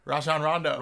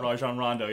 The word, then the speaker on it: Rajon Rondo: I mean, like, I'm not gonna lie, Razan Ramo really rang it. I can't say his name. – Right? Yeah, let's go RR. – Rajon Rondo. – Rajon Rondo, yeah.